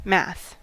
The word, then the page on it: math: American English